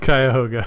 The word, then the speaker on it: Cuyahoga: Cuyahoga? – Cuyahoga.